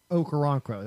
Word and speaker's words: Okoronkwo: Okorocano, I believe is how you say it. Okoronkwo, that's it.